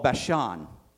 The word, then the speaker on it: Bashan: Well, what you need to know about Bashan, it's spelled B-A-S-H-A-N.